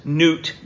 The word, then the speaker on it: Nut: In this plague, God demonstrated his superiority over, and I'm sure it's Nut, but it's spelt N-U-T, Nut, the goddess of the sky.